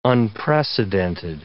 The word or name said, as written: unprecedented